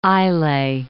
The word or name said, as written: Islay